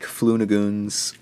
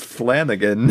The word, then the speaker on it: Flanagan: We watched Mike Flunagoon's Hush. – Why can't you just say his name? – It's Flanagan. – It's Mike Flanagan, yes.